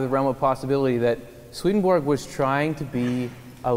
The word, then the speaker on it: Swedenborg: It's pronounced Swedenborg in Swedish.